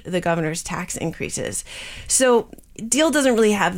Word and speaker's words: Diehl: Ed Deal, or as his campaign t-shirts like to say, Ed No Tax Deal. And I guess we should point out that that's spelled D-I-E-H-L, but it is pronounced like Deal.